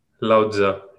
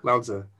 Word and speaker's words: Lao Tzu: Lao Tzu? – Lao Tzu. Yeah. – Lao Tzu.